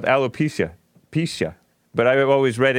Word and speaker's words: alopecia: How do you pronounce it? – It's alopecia. – Alopecia. And I always read it alopecia.